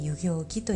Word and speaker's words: 遊行期: 遊ぶに行うと書いて、えー、遊行期と読みます